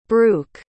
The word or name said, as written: bruch